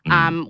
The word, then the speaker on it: Bechdel: And then there's obviously famously the Bechdel test. – Mm. – Is that how you say it? I never know if I'm saying it right. Bechdel. – Yep, Bechdel.